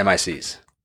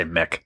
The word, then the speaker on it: MIC: MICs. How do you pronounce that? – I say MIC. – I say MIC, too. – Okay. – Because a mic is what I'm talking into.